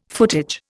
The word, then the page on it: footage: Indian